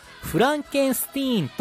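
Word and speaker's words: Frankenstein: 彼は自分があの狂気の科学者フランケンシュタイン博士の孫であることを嫌い、名前もフランケンスティーンと発音して、家系を否定します。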